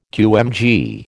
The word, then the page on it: QMG: American English